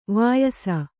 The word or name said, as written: ವಯಸ್ಸು